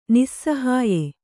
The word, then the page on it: ನಿಸ್ಸಹಾಯ: nissahāya